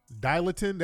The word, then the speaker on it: Dilantin: Dilatin, that's how you say it? Yeah.